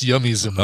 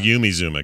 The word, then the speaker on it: Yumi Zouma: Yeah, alt pop, Yumi Zouma, and not pronounced Yummy, by the way, not oh. pronounced Yummy Zuma. Oh no, it's uh, Yumi Zouma.